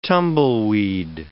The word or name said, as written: tumbleweed